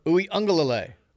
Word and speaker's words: Uiagalelei: I mean, if he comes here, do I have to learn how to pronounce his last name? – Uiagalelei. – I've been calling him Uigui all this time.